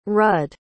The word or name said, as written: rud